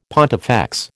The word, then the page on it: pontifex: American English